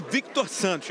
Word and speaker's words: Victor Santos: Eu sempre chamo o senhor de Victor Santos. Eu estou falando certo ou estou errado? – Está certo.